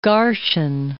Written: Garshin

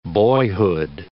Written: boyhood

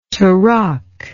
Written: tarok

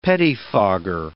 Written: pettifogger

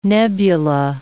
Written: nebula

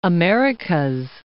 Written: the Americas

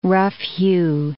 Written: rough-hew

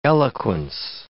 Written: eloquence

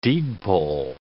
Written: deed poll

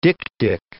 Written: dik-dik

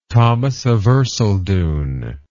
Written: thomas of erceldoune

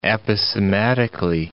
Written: aposematically

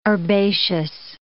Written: herbaceous

